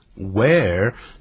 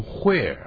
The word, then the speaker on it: where: Where 这个不要念成 where 啊，它那个的音不念出来，我们再念一下 where。